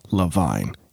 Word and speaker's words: Levine: but I'm just making up pronunciations now. The guy's name is Ken Levine.